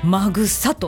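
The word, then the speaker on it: 秣: のぎ辺に松末と書いてマグサと一文字で読む。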